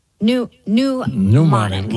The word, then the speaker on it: pneumonic: this pneumonic plague is, you know, you would say it like bubonic, except it's pneumonic. – Like pneumonia, right? – Yep, exactly.